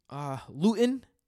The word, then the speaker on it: Luton: So, I mean, it seems like they might be going with Jake Luton. I remember that because it rhymes with gluten.